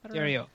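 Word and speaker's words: terroir: Terriers? How do you say that? – Terrio.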